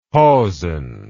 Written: hausen

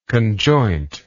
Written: conjointness

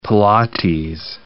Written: Pilates

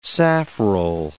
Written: safrole

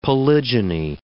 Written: polygyny

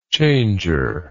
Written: changer

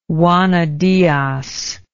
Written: juana diaz

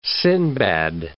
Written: Sindbad

